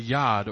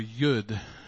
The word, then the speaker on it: yud: This is the yad, or yud, as it's now pronounced in modern Hebrew.